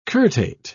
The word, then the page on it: curtate: British English